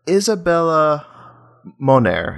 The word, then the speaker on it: Isabella Moner: But I want to point out Isabella Moner.